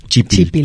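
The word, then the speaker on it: chípil: Justamente de ahí extraemos la palabra chipil para hablar. – Es del náhuatl. – Así es. Uh-huh. Y es una palabra grave, se escribe con acento en la primera I.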